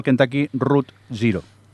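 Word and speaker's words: Kentucky Route Zero: Adri, cuéntanos que has jugado al Kentucky Root Zero, o como se llame eso.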